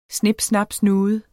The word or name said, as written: snip